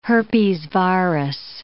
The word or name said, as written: herpesvirus